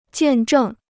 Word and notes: 见证: jiàn zhèng

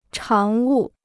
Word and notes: 常务: cháng wù

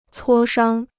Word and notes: 磋商: cuō shāng

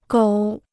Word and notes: 狗: gǒu

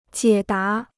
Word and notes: 解答: jiě dá

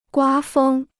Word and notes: 刮风: guā fēng

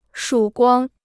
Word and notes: 曙光: shǔ guāng

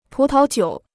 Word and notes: 葡萄酒: pú tao jiǔ

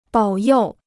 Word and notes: 保佑: bǎo yòu

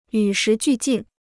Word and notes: 与时俱进: yǔ shí jù jìn

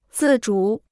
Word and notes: 自主: zì zhǔ

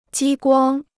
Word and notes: 激光: jī guāng

